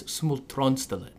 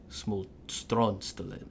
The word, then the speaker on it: Smultronstället: In Swedish, it's Smultronstället. That's how you say it. I have to say it with that weird... – Yeah. – Smultronstället.